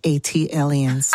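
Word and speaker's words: ATLiens: That is pronounced A-T aliens. – Ah, thank you. – Like Atlanta alien, A-T alien.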